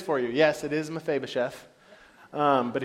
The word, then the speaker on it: Mephibosheth: I'm going to say it one time for you. Ready for this? It's the pressure. Mephibosheth. You're welcome. That's exactly how you say it.